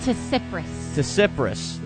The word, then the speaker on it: Tsipras: Tsipras.